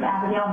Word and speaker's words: Abigail: My name is Ruth Abigail Miller. My name is spelled R-U-T-H-A-B-I-G-A-I-L, last name Miller, M-I-L-L-E-R.